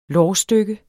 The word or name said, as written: lårstykke